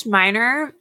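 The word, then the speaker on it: Menier: In 2013, she played the role of Celie Harris in, I'm probably going to pronounce this incorrectly, the Meniere Chocolate Factory production of The Color Purple. – I think it's Meniere. Am I wrong on that? – Meniere? I'll take that. – I've heard Meniere.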